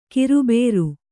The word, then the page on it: ಕಿರುಬೇರು: kiru bēru